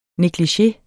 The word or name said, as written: neglige